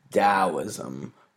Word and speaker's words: Taoism: It is Taoism with a D as in dog. That's how it's pronounced.